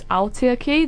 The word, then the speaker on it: Aotearoa: And, you know, after I just had this cultural experience at the art gallery, something really kind of hit and hurt about that really harsh vowel sound, because it's Aotearoa, that's how you pronounce it, or close enough to that.